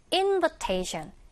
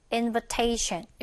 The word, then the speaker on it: invitation: 근데 invitation, invitation 다 아니고요. 여기 바로 앞 모음에다가 강세 줘서 invitation 이렇게 읽어주시면 되겠습니다.